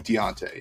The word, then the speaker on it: Deonte: Deontay. – Deontay Banks. – It's, it's spelled like a weird way. Yeah, D-E-O-N-T-E. Deontay, yeah.